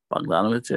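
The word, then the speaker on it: Bogdanović: Bogdanovich, yeah.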